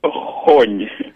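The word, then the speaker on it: xoon: X O O and then N with the N on it. How do you say that? The crispy rice at the bottom. – Hon. You have to come from the back of your throat.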